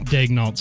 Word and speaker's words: Daigneault: Mark Daigneault. Daigneault. Sorry, I've been saying that wrong my whole entire life.